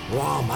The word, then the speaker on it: Ram: Rom. I bet you they call themselves Rom. I can just picture them calling themselves Rom. – I mean, that's how you say it in Swedish.